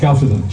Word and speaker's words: Chalcedon: It keeps on going until the Council of Chalcedon, Chalcedon, I don't know how you pronounce it. Chalcedon, yeah? – Chalcedon.